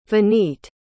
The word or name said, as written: venite